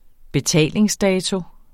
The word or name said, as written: betalingsdato